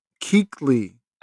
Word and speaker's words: Kuechly: Kaliki is what you said. – What's his name? – Luke Keekley. – Oh, I butchered that name.